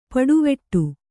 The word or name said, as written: ಪುಡಿವಡು